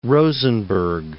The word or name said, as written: Rosenberg